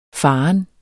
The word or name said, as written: farent